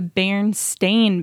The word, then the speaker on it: Berenstain: So it would be Berenstein. – That's how most people remember the Bernstein Bears yes. – books. – But instead, it's the Berenstain Bears with an A.